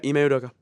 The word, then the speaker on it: Ime Udoka: But Ime Udoka, right? That's how you say it? – Yeah, Ime Udoka.